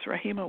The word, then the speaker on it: Rahima: Okay, so just to be really clear, my name is Rahima Warren, and my first name is spelled R-A-H-I-M, as in mother, A, and my last name is Warren, W-A-R-R-E-N.